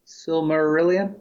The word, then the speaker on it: Silmarillion: Like, I've never, I think I attempted once at the Silmarillion. Is that how you pronounce it? – Yeah.